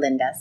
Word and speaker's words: Linda: challenge people to say Linda instead of Linda.